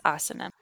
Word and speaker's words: asana: Seriously, and like Sanskrit operates kind of similarly to Spanish. So if you're pronouncing it like you would Spanish, it would be asana because the emphasis would be on the second to last syllable. Mm-hmm. But it's asana.